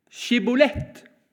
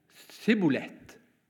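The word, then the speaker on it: Sjibbolet: Da sa de til ham, Si Skibolett. Men han sa, Sibolett, for han kunne ikke uttale ordet riktig.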